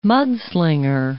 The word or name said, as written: mudslinger